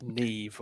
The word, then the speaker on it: Niamh: I like the fact that the Irish go with names that are pronounced completely different to their spelling. It's like Niamh, you know? – Yeah. – When you see people called Niamh and they say, that is not my name. It's Neve or whatever it is.